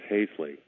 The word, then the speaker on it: Haisley: Hello, my name is Dennis Haisley. It rhymes with Paisley.